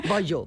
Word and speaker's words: Bayeux: Saltaba la noticia en el periódico The Guardian, que dice así: el Museo Británico permitió una visita privada al tapiz de Bayeux, ¿se pronuncia así? – No, digo yo. Yo de francés, ya sabes que Bayeux.